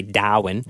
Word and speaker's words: Darwin: The hottest I experienced was Darwin, as the locals say, Darwin.